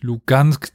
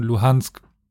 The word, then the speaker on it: Luhansk: Und dann wäre natürlich Lugansk die russische Aussprache und Luhansk die ukrainische.